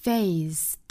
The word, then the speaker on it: faced: 那它加上 ED 了尾部依然是要读成一个清辅音的样子 faced 而不是 faced 所以